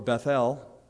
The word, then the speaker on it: Bethel: So the house of Joseph sent men to spy out Bethel, or Bethel, more accurate pronunciation.